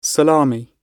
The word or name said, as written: salami